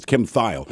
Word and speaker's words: Kim Thayil: And then I saw something with him, and he said, hi, it's Kim Thayil.